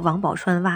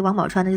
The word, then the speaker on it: 王宝钏: 比如说最近特别流行那个王宝钏挖野菜那个事儿，当然有人说规范的读音叫王宝钏啊，但是咱们大众好像说王宝钏比较多，所以我就还说王宝钏吧。